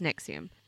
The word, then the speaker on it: NXIVM: And if you want to look this up, it's spelt in a really weird way. It's NXIVM, but it's pronounced Nexium.